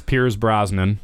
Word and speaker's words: Pierce Brosnan: It's Pierce Brosnan.